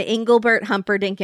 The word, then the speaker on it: Engelbert Humperdinck: Now, the only reason I'm mentioning David is because David was an Engelbert Humperdinck impersonator.